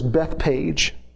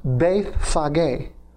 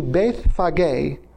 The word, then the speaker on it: Bethphage: As they approached Jerusalem and came to, it looks like this word is Bethpage. I've always struggled with this word, like Bethpage. I know there's got to be a silent there. It's actually pronounced Bethphage. And they came to Bethphage on the Mount of Olives.